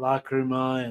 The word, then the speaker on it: lágrima: Lágrima in Spanish. So yeah, Lágrima. Yeah. – Lágrima. You pronounce it like a G, log? – Yeah. In Latin it's Lágrima and in Spanish it's Lágrima.